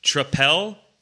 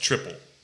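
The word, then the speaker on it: Tripel: Trappel is incorrect in irksome to the internet. It is pronounced triple.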